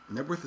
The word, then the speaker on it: Knebworth: Nebworth is not a town, by the way. Nebworth is, I don't know what it is. I don't know why the K is silent.